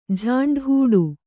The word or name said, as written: ಜಾಂಡಾಹೊಡೆ